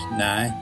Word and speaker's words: now: In Belfast it's now, usually pronounced nigh, and County Antrim and Derry will put hay onto the end.